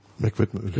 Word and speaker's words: Meg Whitman: Jetzt ist ja der Leo Apotheker dann sofort rausgeschmissen worden und die Meg Whitman, spricht man so aus, ne? – Meg Whitman, würde ich mal sagen.